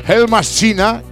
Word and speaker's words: Heldmaschine: Que me lo he puesto en Google para pronunciarlo bien. Hellmaschina, que quiere decir...